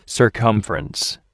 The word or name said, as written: circumference